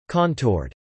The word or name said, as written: contoured